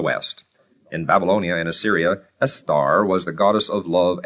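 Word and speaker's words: Istar: The name and cult of the goddess were derived from Babylonia, where Estar, that's I-S-T-A-R, pronounced Estar, represented the evening and morning stars and was accordingly androgynous.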